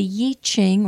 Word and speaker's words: I Ching: Now, some of you have never heard of it, and some of you have heard it pronounced Yi, like Y-I or Y-E-E, Ching, C-H-I-G, and it has to do with changes. So the Yi Ching or the I Ching